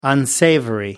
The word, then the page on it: unsavory: British English